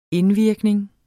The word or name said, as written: indvirkning